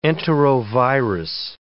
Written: enterovirus

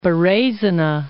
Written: Berezina

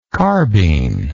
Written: carbene

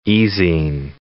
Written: e-zine

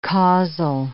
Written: causal

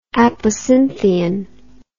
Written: apocynthion

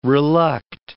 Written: reluct

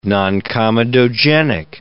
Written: noncomedogenic

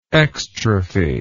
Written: exstrophy

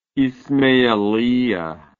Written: ismailiya

